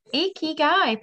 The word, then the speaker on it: ikigai: IKIGAI. Perfect. Well done.